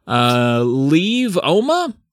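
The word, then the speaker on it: Lieve Oma: Leave Oma? I'm going to spell it. L-I-E-V-E space O-M-A.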